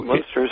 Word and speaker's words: Munster: So Munster is fine.